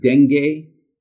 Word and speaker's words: dengue: Is it pronounced dengue fever? – I think that's right.